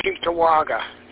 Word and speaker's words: Cheektowaga: Very good, River, on the pronunciation on Cheektowaga.